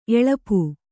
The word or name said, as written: ಎಳಪು